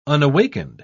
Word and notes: unawakened: British English

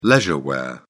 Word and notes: leisure wear: British English

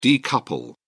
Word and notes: decouple: British English

